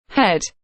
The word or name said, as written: head